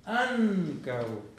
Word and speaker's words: ankaŭ: Ancao.